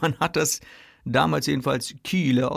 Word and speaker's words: Kyle: Und es das heißt die Holstenstadt an der Kieler Förde. Das heißt das übersetzt, ja. Und damals hat man das noch mit so langem I ausgesprochen. Also K-Y-L-E wird es geschrieben, dann Kiele, Ne?